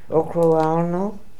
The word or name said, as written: Okrualno